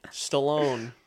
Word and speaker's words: Stallone: Yeah, the best one was like Sylvester Cerrone, Cerrone, Stallone.